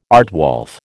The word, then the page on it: aardwolf: American English